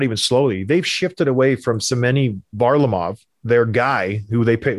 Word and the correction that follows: Semyon Varlamov: Did I butcher the name? – Semyon Varlamov.